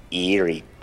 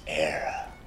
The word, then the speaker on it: Erie: Would you at least learn how to say it? The land to your fathers isn't eerie, Boyle? It's error.